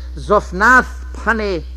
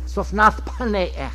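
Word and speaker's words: Zaphnath-Paaneah: And Pharaoh called Joseph's name, it took him time to say this name, for it's Zophnath Paneach. Zophnath Paneach. That's the pronunciation.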